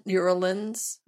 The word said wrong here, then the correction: uralensis: I always Uralensis. Get it wrong. – Uralensis. – Yes, thank you. I always say it wrong.